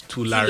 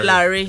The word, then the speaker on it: Tulare: He says the name Tulare, the correct pronunciation is Tulare. – Tulare, yes.